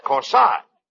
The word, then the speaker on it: corsage: No, not corsage. – Not corsage. – That's corsage. Spelled A-G-E is pronounced like odd, as in corsage or garage.